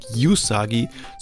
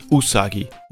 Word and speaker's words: Usagi: Es ist natürlich nicht Usagi, sondern Usagi.